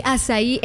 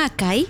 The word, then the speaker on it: açaí: Yo recuerdo la primera vez que vi açaí en algún menú. Recuerdo haber dicho, ay, ¿me puede traer un acai? No sabía la pronunciación.